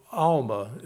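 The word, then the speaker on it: almah: Now, the word virgin, translated in the Hebrew, and Alma is, I believe, how it's pronounced, it means a young woman.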